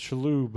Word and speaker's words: Shalhoub: Oh, it's pronounced Shalhoub, eh? Gotcha.